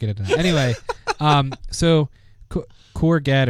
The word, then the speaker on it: Courgette: So anyway, Google or the developers for Chrome have taken it a step further, and they have this new technique called I think you pronounce it Courget. It's Courgette. Courget is how I would say it.